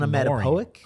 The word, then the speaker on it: onomatopoeic: Onomatopoeic? – Oh, what the use in fuck media. Is that word? – Onomatopoeic, yeah, onomatopoeic. – I think you pronounce it pretty perfectly.